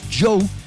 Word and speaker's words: Djo: He's Joe with Basic Being Basic. Joe, the former actor, well, he still acts, but we like his music now. DJO, that's how you spell it, okay, if you want to check some of his music.